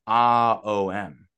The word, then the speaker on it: Om: When you say Om properly, I'm going to do it actually. – Okay. – It's not Om, it's A-O-M. A-O-M.